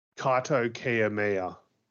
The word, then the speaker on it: Kaito Kiyomiya: Can you help me out with the pronunciation from this one? Representing Noah? – Kaito Kiyomiya.